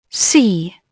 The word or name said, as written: sea